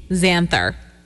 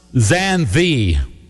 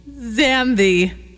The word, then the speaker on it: Xanthe: X-A-N-T-H-E. – Xanther. Oh, Xantha. Not E-R, just Xanth. – Well, Xanth. – You're in the ballpark. You did better than me. I thought it would be Xantha. It's pronounced Xanthi. – Xanthi.